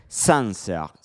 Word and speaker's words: Sancerre: Sanser, sí, es con A, Sancerre.